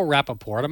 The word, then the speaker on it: Rapaport: This actor goes back, Michael Rapaport.